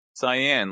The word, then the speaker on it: Cyan: Is it Cyan or Cyan? – Cyan, like the color.